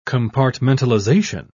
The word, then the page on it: compartmentalization: British English